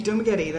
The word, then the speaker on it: Dumaguete: Dumaguete, that's right. – Did I say that right? Dumaguete? – Yes, there's an accent, so I may even say it wrong.